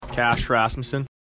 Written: Rasmussen

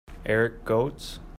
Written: Gotz

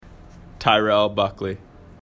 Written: Buckley

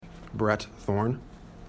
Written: Thorne

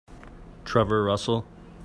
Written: Russell

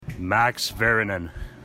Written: Vayrynen

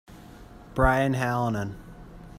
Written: Halonen

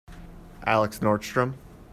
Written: Nordstrom

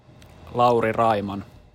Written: Raiman

